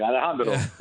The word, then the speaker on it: Alejandro: Alejandro.